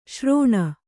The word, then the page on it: ಶ್ರೋಣ: śrōṇa